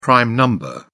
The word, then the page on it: prime number: British English